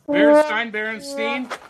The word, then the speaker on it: Berenstain: Is it Berenstain? That's the debate, isn't it? Berenstain.